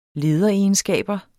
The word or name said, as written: LEDEREGENSKABER